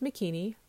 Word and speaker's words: Makini: I say, my name is Makini, rhymes with bikini, but with an M.